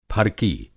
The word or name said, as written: फरकी